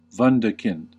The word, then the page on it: wunderkind: American English